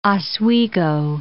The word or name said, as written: Lake Oswego